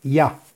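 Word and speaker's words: Yah: de Yud Hei, que se pronuncia Ya.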